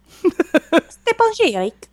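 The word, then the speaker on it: Stjepan Šejić: It seems like it's Stepan Sheik. – Stepan Sheik. – Yeah. he said, like sage, pronounced by Sean Connery.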